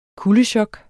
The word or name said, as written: kuldechok